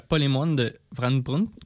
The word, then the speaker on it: polémoine de Van Brunt: C'est la polémoine de Van Brunt, c'est comme ça qu'on la prononce? – Oui.